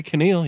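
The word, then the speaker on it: Kaneal: Keneal? How do you spell that? – It's K-A-N-E-A-L. – Okay, Keneal, yeah. – And then Sweetwine is the word sweet with W-Y-N-E.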